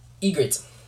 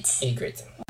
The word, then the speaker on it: Ygritte: I don't think it's Irene. I think you're saying it wrong. – Yeah. Irene? – Fact check number two. Rose Leslie's, you see, and her name. – Character in Game of Thrones. It's Ygritte.